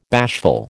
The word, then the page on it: bashful: American English